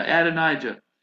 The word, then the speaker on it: Adonijah: Now, even on this occasion of Adonijah, did I get his name right? Yeah, Adonijah. It says very clearly that David never disciplined him.